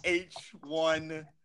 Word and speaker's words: H1Z1: The game he's gonna talk about is H1Z1? – Yes, you said it right.